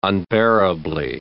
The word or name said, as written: unbearably